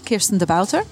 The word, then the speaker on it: Kirsten De Bouter: Right, so you can find me on Twitter, at Kirsten De Bouter. – Can you spell it? – Yes, K-I-R-S-T-E-N-D-E-B-O-U-T-E-R.